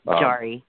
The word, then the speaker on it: Jarry: Jarry, or whatever you pronounce it out there in Pittsburgh. – Jarry.